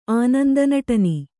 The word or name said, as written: ಆನಂದನಟನಿ